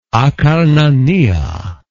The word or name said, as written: Akarnania